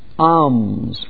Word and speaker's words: alms: A third duty is the giving of alms. Alms, A-L-M-S. Alms. I think the L is silent properly. Alms. Like Psalms. alms.